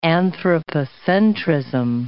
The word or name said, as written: anthropocentrism